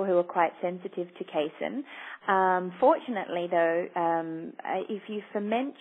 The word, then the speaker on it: casein: But overall, the dairy protein, I don't know how you pronounce it, casein? – Casein, yeah.